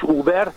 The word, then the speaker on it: Uber: Annyi lenne hozzáfűzni valóm az előző témakörhöz, a taxis meg Uber dologhoz, ugye U-nak ejtik kint is Uber,